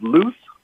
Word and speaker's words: Luce: So I will be the one that says, now how do you pronounce L-U-C-E scholar? – Oh, it's pronounced loose? Yeah. – Loose. Okay. – L-U-C-E, right?